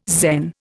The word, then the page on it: Zen: Indian